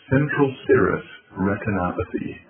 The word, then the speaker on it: central serous retinopathy: He says, "Son, you have what's called CSR, central serous retinopathy."